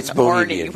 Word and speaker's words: Orny: V-A-N-O-R-N-Y. – All right. It's Orny, which I have always called him.